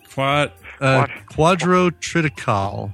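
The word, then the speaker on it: quadrotriticale: The Enterprise is ordered to protect a large shipment of Quadro Tricali. Quadra, how would you say that? – Quadro triticale. – No. What? No. Quadro triticale. – Oh, quadro triticale, which is quadro a wheat rye hybrid.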